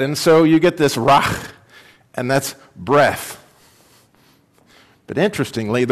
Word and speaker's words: ruach: Now, in Hebrew, the word spirit is pronounced ruach.